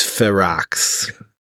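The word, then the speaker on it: Ferox: But how do you say it? I think it is Farrox. – Yeah, it's Farrox.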